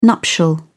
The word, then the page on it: nuptial: British English